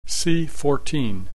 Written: C-14

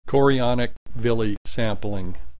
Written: chorionic villi sampling

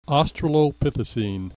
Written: australopithecine

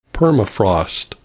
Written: permafrost

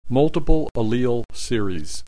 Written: multiple allele series

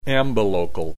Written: ambilocal

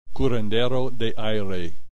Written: curandero de aire

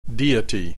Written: deity

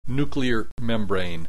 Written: nuclear membrane

